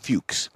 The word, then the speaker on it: Fuchs: It's spelled different, but it's pronounced Fuchs. When Porsche, well, how do you spell it? – F-U-C-H-S.